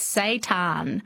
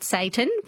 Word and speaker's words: seitan: Well, I'm glad that you're asking me because it's spelt differently to how it sounds. So it's seitan. Not Satan, which will be different if you put that into AI. – S-E-I-T-A-N? – S-E-I-T-A-N. – S-E-I-T-A-N.